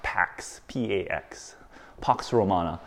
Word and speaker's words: Pax Romana: Some people pronounce it Pax, P-A-X, Pax Romana.